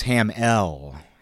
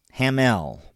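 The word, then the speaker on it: Hammell: And don't confuse him with that famous actor, Mark Hamill, because it's spelled differently. It's H-A-M-M-E-L-L, not I-L-L. And if you're having trouble remembering that, just remember that Mark Hamill is ill. Ham-ill. Ham-ill! This is Ham-L. Hamill. Ham-L.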